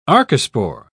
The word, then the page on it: archespore: British English